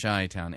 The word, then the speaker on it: Chi-Town: Somebody said I was too Greek when I pronounced it Chi. It's Chi-Town.